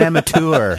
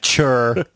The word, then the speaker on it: amateur: He's an amateur. – Amateur. – Amateur, not chur. We know how to pronounce the king's English here, okay? – The bloody king.